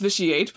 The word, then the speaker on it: Vitiate: It's pronounced Vitiate, but I like Vitiate as like a proper noun.